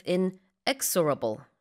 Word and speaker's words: inexorable: Same with inexorable. The stress is on the second syllable. 자, 멈출 수 없다, 거침없다. There's been an inexorable rise when it comes to Anthropic in the past couple of months, it says.